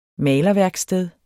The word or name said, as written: malerværksted